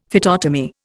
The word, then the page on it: phytotomy: Indian